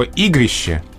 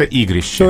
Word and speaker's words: игрище: Есть слово «игрище» угу. – с э, буквы «е» на конце и с ударением на первом слоге.